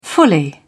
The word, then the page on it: fully: British English